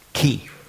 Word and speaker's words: Chi: Chi, das hat man eigentlich ausgesprochen, Ki. Nicht Schweizerdeutsch, Chi. Also nicht Chuchichastli in dieser Art, sondern in Ki.